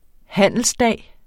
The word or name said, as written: handelsdag